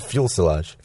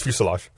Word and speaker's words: fuselage: You said fuel silage. – Fuel silage. – Is it? – It's fuselage.